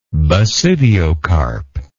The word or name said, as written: basidiocarp